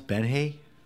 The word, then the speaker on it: Benje: This was the 2017, how do I pronounce, Benje. Because this is a native language that came from the native people. – Oh, is that? – So it's how they pronunciation.